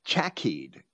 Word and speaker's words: chaciyd: That other word is chakied, C-H-A-C-I-Y-D. And I would pronounce the C as a hard C, not as a soft C. Chakied.